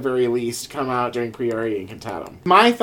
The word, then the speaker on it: Priori Incantatum: Priori Incantatum. – Sure. – That's right? Sure. Is that right, Latin lady? – Priori Incantatum.